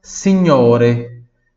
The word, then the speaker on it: signore: Muito bem, próxima palavra, signore. Signore. Repita, signore. Mais uma vez, signore. Fácil, né? Se escreve S-I-G-N-O-R-E.